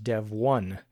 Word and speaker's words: Devuan: Yeah, so the folks that forked it are calling it Devuan, Devuan, which according to the information they publicized on it, it's not Devuan, as you might think. It's actually supposed to be pronounced Devuan.